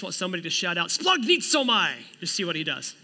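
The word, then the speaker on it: splagnitzomai (σπλαγχνίζομαι): Anybody want to take a stab how to pronounce that? It's the word splagnitzomai. Okay, now it's your turn. Ready? Let's say it with me. Ready? Splagnitzomai.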